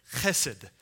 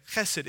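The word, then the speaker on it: Chesed (חסד): Chesed. Okay? If you're not saying it, if you're not spitting on the person in front of you, you're not saying it right. It's in the guttural. Okay? Chesed.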